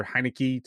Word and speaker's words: Heinicke: They forced Taylor Heinicke to, I can't say his name.